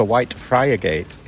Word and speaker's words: Whitefriargate: When you're from Hull, it's Whitefriggate, isn't it? But yeah. When you see it written down, then visitors come and pronounce it correctly. They say we're looking for Whitefriargate.